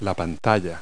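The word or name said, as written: display screen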